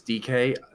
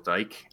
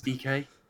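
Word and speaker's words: Dike: So I believe it's DK. I know a lot of British people just say it's Daryl Dyke. I think it's DK. – Yeah, okay. We'll go with DK.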